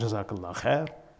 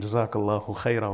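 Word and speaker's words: Jazakallahu khaira (جزاك الله خيرا): So it's a gross mispronunciation. It shouldn't be, it's not Jazakallah khair. It's Jazak khairan, Jazakallahu khaira or Jazakumullahu khaira. Our, our teachers used to emphasize this.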